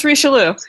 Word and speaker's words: Richelieu: Specifically mentioned is Quebec City, Sherbrooke, St. Jean sur Richelieu, and I apologize for butchering that pronunciation. It's Richelieu.